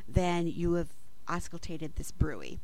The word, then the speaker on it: bruit: It's a French word, so it's pronounced bruit, not bruit.